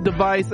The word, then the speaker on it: DEV_IS: no longer Hololive device, which is not spelled actually device, D-E-V-I-S. Devis, device, it's pronounced device.